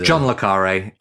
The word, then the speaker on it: Le Carré: Jean Le Carré.